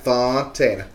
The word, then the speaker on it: Fontana: You gotta say it like that. Fontana.